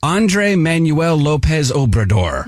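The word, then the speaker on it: Andrés Manuel López Obrador: Andre Manuel Lopez Obrador.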